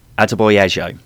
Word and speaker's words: Adebayo: But is Adebayo? No? – Adebayo, I think it's pronounced.